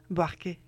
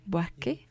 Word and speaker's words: Buarque: De Chico, ¿cómo se pronuncia? – Buarque. – Buarque.